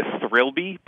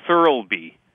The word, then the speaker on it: Thirlby: So it's like, okay, so the girl who plays Anderson in the movie's name is, I keep saying, want to say her name is Olivia Thirlby, but it's not. It's Thirlby.